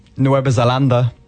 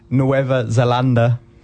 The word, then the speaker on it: Nueva Zelanda: Nueva Zelanda. – Say that again. – Nueva Zelanda.